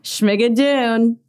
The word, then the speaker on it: Schmigadoon: Schmigadoon? – Schmigadoon. You got it.